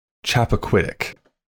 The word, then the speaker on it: Chappaquiddick: I'm not going to say how I did it, but I think you're supposed to call it Chappaquiddick.